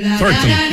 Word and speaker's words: Thornton: Thornton. Me gustó la pronunciación.